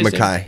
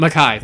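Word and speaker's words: Mekhi: So how do you say Mackay. – His name? Mackay. – Yeah.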